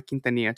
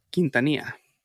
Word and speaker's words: Quintanilla: And on that last time, whether it was 15 or 3, she finally got Abraham Quintanilla to agree to a meeting with her to discuss the details of starting the official Selena fan club. – How do you say their last name? – Quintanilla.